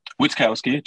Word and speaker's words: Witkowski: Witkowski, just pronounce it like an American would.